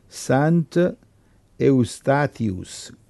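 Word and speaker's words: Sint Eustatius: Sant Eustatius con la T, e l'altro Saint Martin, MARTEN.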